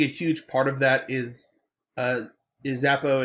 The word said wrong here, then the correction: Zabka: and or it's not Zappo. What's I just said his name wrong.